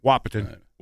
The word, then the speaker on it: Wahpeton: Wahpeton? – Yes. – Is that how you pronounce that? – Sucky part. – Wahpeton. – Wahpeton? – Yeah.